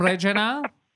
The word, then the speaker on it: Regina: That's That's because you're getting mad at people's pronunciation of Regina and Calgary." – Hello, you're from Regina? Excuse me, ma'am. That's Regina, you fucking.